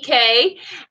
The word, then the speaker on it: Coco Cay: I would say I was going to start calling it Perfect Day at Coco Key Cay. Because it's supposed to be pronounced key, but, you know, it doesn't rhyme with day.